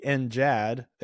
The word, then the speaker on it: NJAD: So so we're doing the NGAD thing, and then there's the Navy has the NJAD. It's pronounced the same, but with a soft G.